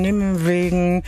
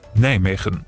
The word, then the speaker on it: Nijmegen: Denn ich sage ja irgendwie nur so eine Mischung zwischen nimm wegen, ich nuschel mir da immer gerne eins zurecht. Richtig ausgesprochen, die Niederländer sagen so. – Nijmegen.